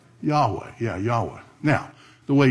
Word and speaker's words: Yahweh: So it sounds like yeah, ho, va, but it's actually the word Yahweh.